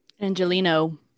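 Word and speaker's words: Angeleno: So, and Josie appreciates you because you're an Angelinan. Is that how you say it? – Angelino.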